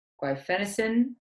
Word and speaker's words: guaifenesin: When we talked originally about both the, I'm going to say it right, guaifenesin protocol.